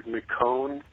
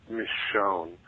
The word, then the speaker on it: Michonne: It's not McCone, it's Michonne, because of, like, French Louisiana naming sense or something. I'm not 100%, but I know that's how they pronounce it in the show.